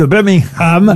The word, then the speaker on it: Birmingham: sans nous dire que c'est la ville de Birmingham.